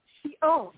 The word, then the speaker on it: Sheol: Sheol, right? Sheol? – Sheol is the original word of hell, that is the word of Hebrew. Sheol is what it was pronounced in the beginning.